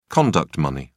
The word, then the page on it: conduct money: British English